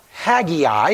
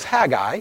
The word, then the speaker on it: Haggai: I encourage you to open your Bibles to Haggai, not Haggai, it's just two syllables, Haggai.